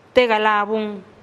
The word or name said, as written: te galabun